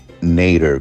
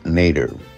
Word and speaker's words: Nater: lo que pasa es que en inglés mi apellido mm, lo pronuncia, lo pronuncia Nader como later, Nader, porque se escribe como la palabra en inglés later, L-A-T-E-R.